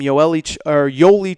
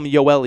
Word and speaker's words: Yoeli: So their big center down low is a kid named Yoeli Childs. I always call him Yoeli because it looks like it's spelled Yoeli, but it's Yoeli Childs.